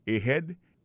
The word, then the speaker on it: ahead: Ahead. Ahead, là, c'est A-H-E-A-D. Ahead. Ahead. Go ahead. Là encore, on a vu que l'accent tonique là tombé sous deuxième syllabe.